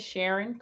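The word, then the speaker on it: Sharyn: Well, hey, guys, my name is Sharon Combs. Sharon is spelled with a Y, S-H-A-R-Y-N.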